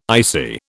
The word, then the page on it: ICI: British English